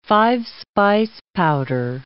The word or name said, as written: five-spice powder